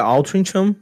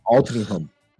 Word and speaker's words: Altrincham: É, eu achava que era Altrincham, but é, é, mas é Altrincham.